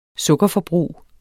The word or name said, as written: sukkerforbrug